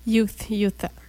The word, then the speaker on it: Utah: C'est Utah. – Alors, UTE. Utah. – Utah. – Et voilà. – Et voilà. – On est au courant maintenant pour le prononcer comme faux.